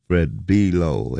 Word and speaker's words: Below: Fred Below, as I have found out, is the proper pronunciation for his name.